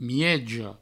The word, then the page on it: mieja: miejo